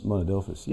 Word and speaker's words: Monadelphous: How do you pronounce it? Monadelphous? – Monadelphous, yeah.